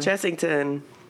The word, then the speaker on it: Chessington: Chessington. Is that pronounced correctly? – Chessington.